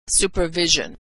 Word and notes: supervision: zh

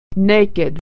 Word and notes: naked: ed

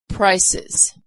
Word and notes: prices: Iz

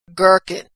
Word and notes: gherkin: g

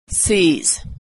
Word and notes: sees: ending z

